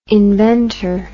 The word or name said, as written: inventer